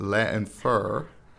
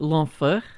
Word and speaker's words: L'enfer: Okay, uh, I'm gonna try to I'm gonna butcher this name, but Let Infer by Stromae. Did I get that right? Did I get close? – Yeah, I think it, I think you would be like l'enfer. – L'enfer.